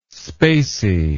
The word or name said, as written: spacey